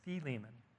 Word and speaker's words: Philemon: But I've often called it Philemon. It's Philemon. So anybody that's interested, it's Philemon is how it's pronounced in the original language.